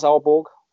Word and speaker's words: Aalborg: It's pronounced Aalborg.